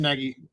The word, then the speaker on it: Nagy: I know Jim Nagy, I think I'm saying his name right. – Jim Nagy.